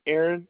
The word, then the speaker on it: Aaron: It's Aaron, A-A-R-O-N, and last name Jordan, J-O-R-D-A-N.